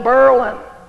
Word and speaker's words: Berlin: For example, Berlin, Vermont, the locals called it Berlin, like Merlin.